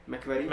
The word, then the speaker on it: McQuarrie: Jinak už se kolem, kolem Bonda objevují jména, jsou tam nějací tři režiséři, jeden je neznámý, pak tam padl, hodně se mluví o Christopherovi McQueerovi, McQuarrie, McQuarrie.